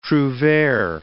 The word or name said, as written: trouvere